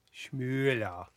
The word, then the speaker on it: Schmoller: Schmoller. – Schmoller? Yeah, with the O-E there. – Thanks for correcting German pronunciation there.